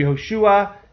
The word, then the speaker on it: Yehoshua: According to Rabbi Yehoshua, it was created in Nisan. Did I say that right? Yeah. Yehoshua said it was created in Nisan.